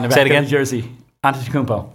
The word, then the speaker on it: Antetokounmpo: Antetokounmpo. Giannis Antetokounmpo. – If you've seen it, I'm still doubting that's how you pronounce it. – That is how you pronounce it.